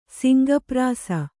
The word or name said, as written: ಸಿಂಗಪ್ರಾಸ